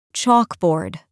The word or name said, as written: chalkboard